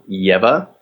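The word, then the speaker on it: Lleva: My name is Samuel Yeva. The Yeva. Double L is like a Y.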